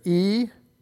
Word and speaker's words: Ecclesia: To put it into English letters, it's ECCLESIA. That's okay. Sometimes the C's, you may see it with K's instead of C's. Ecclesia. There's three syllables in there.